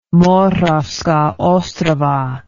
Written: Moravska Ostrava

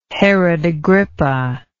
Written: herod agrippa

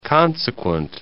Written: consequent

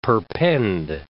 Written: perpend

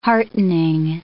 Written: heartening

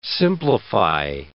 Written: simplify

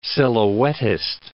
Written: silhouettist

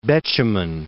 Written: Betjeman

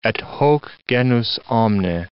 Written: et hoc genus omne

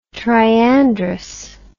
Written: triandrous

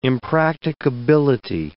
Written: impracticability

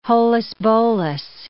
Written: holus-bolus

